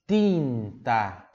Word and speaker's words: tinta: O que você tem que tomar cuidado é aqui no som do ti, pronúncia pura do ti. Il fondo tinta.